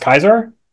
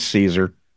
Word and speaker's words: Caesar: Someone told me that it's actually pronounced Kaiser. I don't know if that's true. – No, it's Caesar.